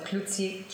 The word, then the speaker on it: Cloutier: My last name first, so Klutzi Kim. – Can you spell that for people? – C-L-O-U-T-I-E-R Kim.